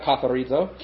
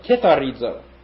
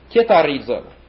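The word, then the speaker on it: kitharizo (κιθαρίζω): Next we have katharizo. Not katharizo, kitharizo. Kitharizo. K-I-T-H-A-R-I-Z-O. Hadn't thought of that. Katharizo is to cleanse.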